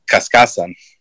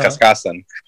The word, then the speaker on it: Cascasan: I've been told by different family members how it's pronounced. For example, I think my dad had told me that the way it's pronounced is Cascasan, right? Uh-huh. Cascasan. And then I've had a cousin from the Philippines that told me no, it's actually we pronounce it Cascasan.